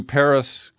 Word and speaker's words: Perris: I've never been to Perris, California. It's spelled P-E-R-R-I-S.